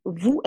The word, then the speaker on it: vous: But however, in some other Creoles, they would use vous as well, but they would drop the S. In French, the vous is spelled V-O-U-S, but in the Creoles where they keep the vous, they would just drop the S.